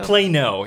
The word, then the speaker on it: Plano: Did you know them out in Plato or Plato? How do I pronounce that? – Plato? – Plano. – Plano. – Is that it? – Plano. – Plano. Sorry about that.